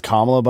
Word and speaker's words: Kamala: It's Kamala, by the way, and the fact that you mispronounce her name.